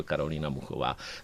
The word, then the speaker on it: Karolina Muchová: French Open a řekl, ať ji vyslovují správně. A on se opravil a řekl Karolina Muchová.